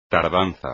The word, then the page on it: tardanza: espagnol